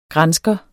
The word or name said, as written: GRANSKERE